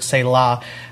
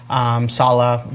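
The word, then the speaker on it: salach: it's the Hebrew word selah, salah, different ways you can pronounce it, but ultimately it's used over 50 times.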